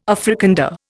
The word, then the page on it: Afrikander: Indian